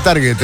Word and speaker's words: target: No, se pronuncia target en inglés, no se preocupe.